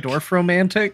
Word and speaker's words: Dorfromantik: we call it Dorfromantik? – Dorf.